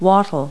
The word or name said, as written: wattle